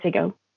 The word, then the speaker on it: Ziggo: Am I pronouncing that right? – Yes. Okay. It's Ziggo.